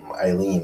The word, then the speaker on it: Eileen: Is it Eileen? – Yeah. Eileen Davidson.